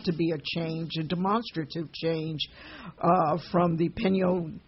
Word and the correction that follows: Peña Nieto: I keep doing that. I am not pronouncing it correctly. Help me, Laura. – The Peña Nieto.